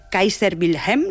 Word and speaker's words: Kaiser Wilhelm: que está sobre la iglesia Kaiser Wilhelm. ¿Lo he dicho bien? – Lo has dicho muy bien, es una iglesia que está...